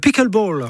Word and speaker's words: pickleball: Avant cela, dans Ici Sport, on va parler de ce nouveau sport tendance, alors qu'on appelle le pickleball. – Oui, vous prononcez bien.